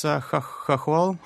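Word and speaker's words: chokhol (хохол): There's a Ukrainian word that you write about, kokol. I don't know if I'm pronouncing it right. – It's chokhol. – Chokhol.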